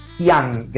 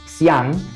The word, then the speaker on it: Xi'an: La tercera ciudad es Xi'an, que se escribe con X, Xi'an,